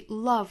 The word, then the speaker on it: love: 那我们注意 love 这个词 ,L-O-V-E。这个词的话，英式发法我们说到是倒三角的 r 的发音，跟 come 是一样的，love come。但是美式发音呢，有可能把这个音的倒三角的 r 这个元音呢，发发成一个 schwa sound，也就是发成 love， love 这个单词。